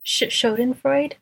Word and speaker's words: Schadenfreude: Schadenfreude? – Yes, thank you.